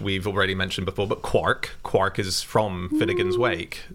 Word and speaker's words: quark: And then he saw the word quark in Finnegan's Wake, where it clearly rhymes with Mark. But even though he spelt it in the same way that Joyce did, he always pronounced it quark.